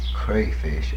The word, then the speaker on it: crayfish: And crayfish, actually, I believe is the way it should be pronounced, but I still say crawfish.